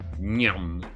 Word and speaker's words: Nghiem: N-G-H-I-E-M. Nyum. It sounds like a Russian name. – N-G-H-I-E-N? – Yeah.